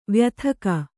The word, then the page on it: ವಯೋಧಿಕ: vayōdhika